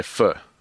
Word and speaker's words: pho: Now, how do you pronounce P-H-O? – I believe it's pho. I think they say pho. – They say pho. – Yeah. That's the proper Vietnamese pronunciation, I believe. – Yeah. – But I would, being a gringo, I would say pho.